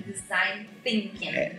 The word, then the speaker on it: Design Thinking: Só que eu acho que o mais importante depois de ouvir essa entrevista é pronunciar Design Thinking. – Design Thinking. – Não existe no português esse fonema, né? – É que, na verdade, nós não temos o...